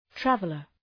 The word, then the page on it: traveler: ‘trævələr